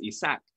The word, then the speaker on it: Ishaq: So like my name is spelled Ishaq, which is like phonetic to how you would say it in Arabic. You would pronounce the H, Ishaq, in Arabic. But I grew up in a mixed household. So I grew up in speaking Spanish, actually. And in Spanish, the name is Ishaq. And that's how I grew up, always pronouncing it.